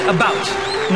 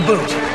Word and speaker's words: about: I speak English and French, not American, and I pronounce it about, not a boot.